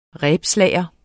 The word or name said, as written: rebslager